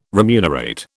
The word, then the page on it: remunerate: British English